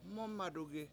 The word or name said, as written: MOOmadugi